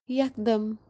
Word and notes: yakdam: Kashmiri

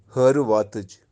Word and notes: Hariwaatij: Kashmiri

